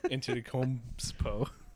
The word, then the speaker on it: Antetokounmpo: Antetokounmpo. – Antetokounmpo. – Stop. You say it wrong on purpose, like my grandma. – No, I promise that's the way it's pronounced.